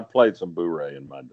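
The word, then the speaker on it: bourré: Uh, B O U R E with the accent. – Oh, it's after it's after the Cajun card game. That's in Louisiana. That's pronounced boure, but I, okay.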